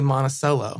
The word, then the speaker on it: Monticello: So I guess once you visited, was it the Monticello Lounge? – Yeah, Monticello. Monticello. Say it wrong. Yeah, it's named after Jefferson's home. – Okay, but we say Monticello.